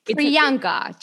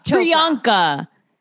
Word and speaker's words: Priyanka: Pananka. I'm so bad at names. Pianka, right? – No. Priyanka. – Priyanka.